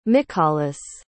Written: mikhalis

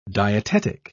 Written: dietetic

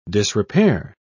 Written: disrepair

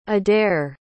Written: adare

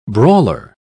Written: brawler

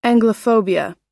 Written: anglophobia

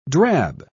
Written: drab

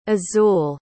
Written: azul

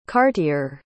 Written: cartier

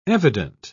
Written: evident

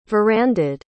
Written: verandaed

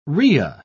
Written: rhea

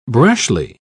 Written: brashly

